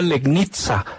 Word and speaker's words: Legnica: Para el Mietz Legnica se escribe, pero dicen que se pronuncia Legnica.